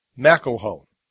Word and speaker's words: McElhone: And actually, it's the only female role in the whole movie is Natasha McElhone. And I did pronounce her name right because I actually made a point of going out and researching it, and that's how you pronounce it.